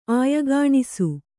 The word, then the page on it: ಆಯಗಾಣಿಸು: kannada